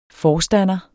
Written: forstander

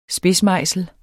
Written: SPIDSMEJSEL

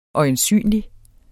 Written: øjensynlig

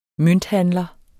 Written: mønthandler

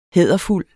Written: hæderfuld

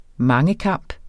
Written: mangekamp

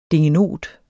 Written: DINGENOTER

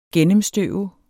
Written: gennemstøve

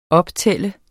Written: optæller

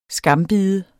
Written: skambide

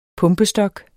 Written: pumpestok

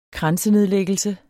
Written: kransenedlæggelse

